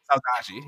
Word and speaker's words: saudade: Saudade, yeah.